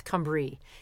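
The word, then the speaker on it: Cymru: And the name Cambria is the Latinized form of the original Celtic name for Wales, which is Cambria, or it's Cumbria is how they say it.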